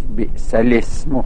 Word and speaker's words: بئس الاسم: بئس الاسم می نویسد ولی در تلفظ به سلسمو خونده میشه، همزه میفته.